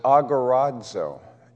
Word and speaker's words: agorazo: And the word for redemption was agorazo. And so they would take agora, and the zo would be the omega, but we put a long O on that.